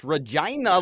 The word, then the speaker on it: Regina: It's like, hey, if you wanted people to say the name right, we got to tell them what it rhymes with. It rhymes with vagina. It's called Regina. I'm tired of people coming to my town and telling me how to say the name of my town when my town is said this way. This is wrong and not right. It's Regina, like vagina.